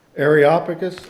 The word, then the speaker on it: Areopagus: How do you pronounce that again? Areopagus? – Areopagus, okay.